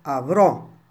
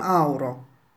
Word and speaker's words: avrò: Io avrò una grande casa con la piscina. State attenti, la parola da pronunciare è avrò, non auro. Auro in italiano non significa niente.